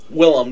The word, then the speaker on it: Willem: The rat is William Dafoe? – Willem Dafoe.